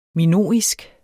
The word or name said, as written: minoisk